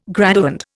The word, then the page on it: graduand: Indian